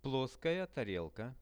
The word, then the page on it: ploskaja tarelka: Russian